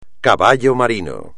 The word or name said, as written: caballo marino